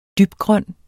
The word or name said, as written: dybgrøn